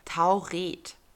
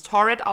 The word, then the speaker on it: Taured: Der Name des Landes, das den Pass ausgestellt hat, lautet Tauret. Tauret. Tauret, ja. Also die Geschichte findet man meistens im Englischen, dann würde man es Torred aussprechen, aber man schreibt T-A-U-R-E-D, also Tauret.